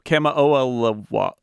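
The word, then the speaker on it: Kamo'oalewa: It's Kamo'oalawea. No, Kamo'oalawa. Kamo'oalawa. Hawaiian is actually very easy to pronounce, but you just have to count all the vowels and pronounce them all.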